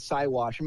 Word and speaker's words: Siwash: I believe it's Siwash.